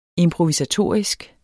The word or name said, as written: improvisatorisk